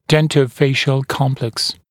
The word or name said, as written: dentofacial complex